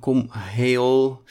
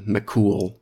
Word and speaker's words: Cumhall: Cumhall. Het wordt gewoon uitgesproken als McCool of Cool. Het is niet één klank. Het is een beetje Cool.